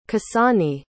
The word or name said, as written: kasani